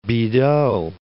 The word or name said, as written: Bidault